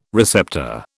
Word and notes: receptor: British English

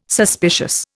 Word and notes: suspicious: Indian

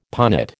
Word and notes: punnet: American English

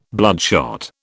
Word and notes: bloodshot: British English

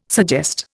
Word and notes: suggest: Indian

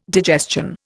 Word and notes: digestion: Indian

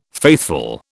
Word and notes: faithful: British English

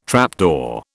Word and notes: trapdoor: British English

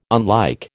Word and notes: unlike: American English

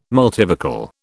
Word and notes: multivocal: British English

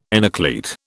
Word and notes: enucleate: British English

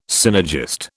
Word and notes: synergist: British English

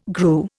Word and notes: grew: Indian

